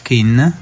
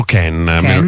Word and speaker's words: Kean: Moise Kean, o Ken, non so esattamente come si pronunci. – Credo Ken, i telecronisti lo chiamano Ken.